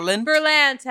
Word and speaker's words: Berlant: Berlant, as in lantern.